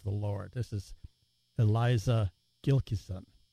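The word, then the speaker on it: Gilkyson: Sort of alternative indie folk singer-songwriter Eliza Gilkison, up Gilkison, there's no N in it, I mispronounced it, Gilkison, from her album Seculera.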